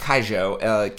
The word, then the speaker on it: Keijo: You need to find, it's actually pronounced Kaijo, K-E-I-J-O, or you can look it up as Hip Whip Girl.